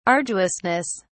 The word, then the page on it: arduousness: British English